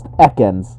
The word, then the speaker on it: Ekans: Yes, so it is pronounced Ekans.